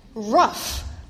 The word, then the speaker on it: roof: That word is R-O-O-F. Now, I grew up in the Midwest, outside of Chicago, to be precise. You know how they pronounce that word there? Rough.